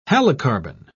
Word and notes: halocarbon: British English

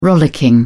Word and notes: rollicking: British English

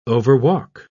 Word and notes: overwalk: British English

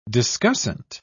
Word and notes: discussant: British English

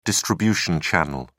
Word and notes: distribution channel: British English

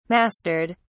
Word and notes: mastered: British English

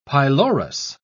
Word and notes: pylorus: British English

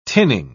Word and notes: tinning: British English

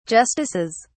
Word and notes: justices: British English

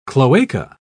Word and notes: cloaca: British English

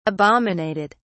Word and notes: abominated: British English

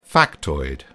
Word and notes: factoid: British English

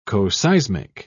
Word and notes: coseismic: British English

